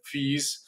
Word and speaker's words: Fico: Come tutti ormai saprete, il primo ministro slovacco Robert Fis, in realtà si pronuncia così, anche se tutti scrivono Fico, io sono indeciso su come pronunciarlo oggi durante la trasmissione, vedrò strada facendo.